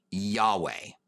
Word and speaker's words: Yahweh: and would be pronounced, it seems, in Hebrew, Yahweh. That's what it seems like it would be pronounced.